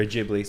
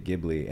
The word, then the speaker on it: Ghibli: Or Ghibli, sorry. See, I pronounced my cat's name Ghibli. When we named the cat, we named it Ghibli. And then people were like, it's pronounced Ghibli if you're trying to go for the movies.